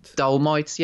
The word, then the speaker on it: Dolemite: Yeah, phonetically, you'd probably say dole. Dolomite, yeah, that's what I was, yeah.